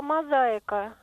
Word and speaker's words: мозаика: Лариса, вот слово «мозаика», ударение на «а».